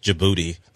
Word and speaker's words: Djibouti: It is spelled where you could pronounce it Djibouti. – Yeah. – But it's just Djibouti. I think the D is silent.